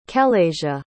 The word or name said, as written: kelayshia